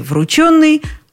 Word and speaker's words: врученный: Что касается ударения, то в причастии «врученный» оно тоже без вариантов.